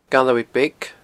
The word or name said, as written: Galloway Beag